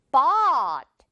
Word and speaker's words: bought: 그래서 샀다가 되면서 발음은 지금 이 부분 옷 이렇게 발음되지 않고 화끈하게 입 확 벌려주세요. 그래서 bought 이렇게 발음이 됩니다. bought 아니고요. 그리고 bought 아니죠. bought도 아니에요. 그냥 어, 이 발음, 이 떡 발음 그대로 유지하면서 마지막에 T 처리만 해준다라고 생각하면 돼요.